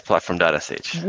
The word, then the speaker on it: platform.sh: So you work at Platform, is it pronounced platform.sh? – Yes, platform.sh.